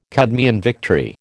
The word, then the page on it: Cadmean victory: American English